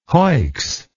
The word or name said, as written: hoicks